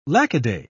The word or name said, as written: lackaday